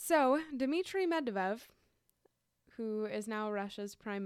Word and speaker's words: Medvedev: The Dmitry Medvedev disclosure. Medvedev? – It's Medvedev.